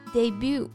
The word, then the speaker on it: debut: 那这里呢，我们可以学到两个表达。第一个是 debut，那 debut 这单词就是首次亮相、初次登台的意思。 – 没错，我们要注意这个单词的发音，不要被它的拼写给迷惑，读成这个 debut，应该是 debut，debut。 – 嗯，因为它是一个法语外来词。那第二个音节呢，不要按英语发成 but，而是 bu，bu。词尾的字母 t 不发音，所以是 debut。